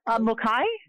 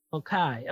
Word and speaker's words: Mulcahy: Mulcahy. – Mulcahy. Okay. – Mulcahy.